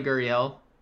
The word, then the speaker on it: Gurriel: I was looking at Lourdes Gurriel. – You get the name right. That's it, I think, for me. – I would play Gurriel.